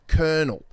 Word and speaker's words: colonel: These kids were from Brisbane, and I held up the word colonel. Now, the tricky thing about colonel is it's not spelt with an R. It's C-O-L-O-N-E-L.